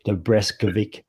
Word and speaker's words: Dobreskovic: All right, I'm sure I won't do this pronunciation justice, but this week's guest, Brett Dobreskovic.